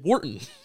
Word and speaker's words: Wharton: Wharton School. – That's in Penn. – Is it? – Wharton.